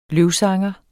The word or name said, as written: løvsanger